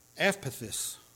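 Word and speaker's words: eupeithes: But it's epithys. I probably messed that up. It's E-U-P-E-I-T-H-E-S. And it means persuadable. Persuadable. Epithys, I think is how it's pronounced.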